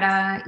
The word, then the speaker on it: stridor: Stridor.